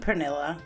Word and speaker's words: Pernilla: I've been told that you don't really catch my name when I say it. It is a Swedish name, and it's spelled P-E-R-N-I-L-L-A. Pernilla. So now you know.